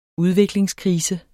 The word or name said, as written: udviklingskrise